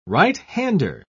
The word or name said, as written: right-hander